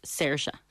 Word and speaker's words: Saoirse: But I told him my drama teacher said the correct pronunciation is Saoirse.